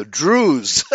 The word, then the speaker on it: Druze: Oh no! The Druze. you're pronouncing it as Jews. That's what I heard. The Druze. Yeah, Druze. D-R-U-Z-E. Yeah, and I could see it could be pronounced Jews. right. But it's the Druze.